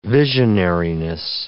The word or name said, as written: visionariness